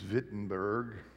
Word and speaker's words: Wittenberg: And so there in, it's spelled Wittenberg, but in German I think it's Wittenberg, he tacked his 95 theses on the door there at Castle Church.